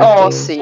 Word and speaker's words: Ozzy: Não é Ozzy, Ai, é Ossi.